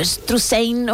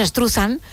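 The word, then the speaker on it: Struzan: Bueno, pues Drew Struthan, o Struthan, o Struthain, o como cada uno lo quiera pronunciar, nosotros decimos Struthan. – Struthan, que es más fácil.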